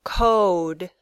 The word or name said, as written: code